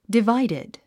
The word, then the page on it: divided: diváidid; ディバイディド